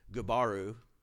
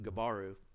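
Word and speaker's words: Gubaru: He's probably Cyrus's general, Gabaru. It's like Subaru with a G on, on front. It's pronounced a little different, Gabaru.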